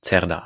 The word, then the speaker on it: Cerdà: Daher entstand 1860 eine neue Stadtplanung, der Plan Cerdà oder Cerdà ausgesprochen.